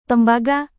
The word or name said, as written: tembaga